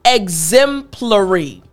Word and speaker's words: exemplary: So, I want to break down the pronunciation of this word. Again, this word, it's a little bit longer, but I can help you pronounce it properly like a native English speaker. So again, the word is exemplary. Exemplary. Now, I want to focus on the pl portion of this word. Exemplary. Because with the PL, there are two sounds that I want to help you pronounce properly.